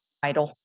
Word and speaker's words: Idle: Idle. – Could you spell that for me? – Yeah, it's just like it sounds, I-D-L-E.